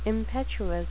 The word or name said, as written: impetuous